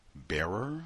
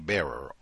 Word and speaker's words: Barer: Uh, it looks like Colson Whitehead's wife, uh, Julie Barer, I think is how you say her name, B A R E R, Julie Barer.